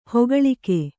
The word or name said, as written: ಹೆಕ್ಕಳಿಕೆ